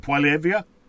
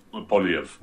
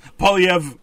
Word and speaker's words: Poilievre: Now, because you're French, how do you say his name? Poiliev? – Poiliev. I he, he, says Polyev. I totally butchered that. – so Polyev, Polyev, uh, say that for me again.